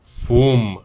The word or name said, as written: fum